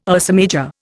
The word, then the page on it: Ursa Major: Indian